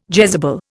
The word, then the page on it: Jezebel: Indian